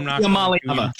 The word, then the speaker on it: Iamaleava: Nico Iamaleava. – Try. Iamaleava.